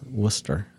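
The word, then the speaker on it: Wooster: And it's spelled like rooster, but not pronounced like rooster. It's Worcester.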